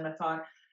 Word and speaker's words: Methot: Which is just my name, of course, Suzanne Methot. But everything's linked on my website. So SuzanneMethot.ca. And the last name is M-E-T-H-O-T. Yes, I'm pronouncing it in English.